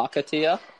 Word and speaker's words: Acacia: And then the other ones I like are the I'm going to say this wrong too, but the Akio ones. The ones that are like really spiny, and then they've got the red blossoms at the top. Uh, Acacia. – Acacia.